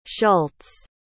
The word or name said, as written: schultz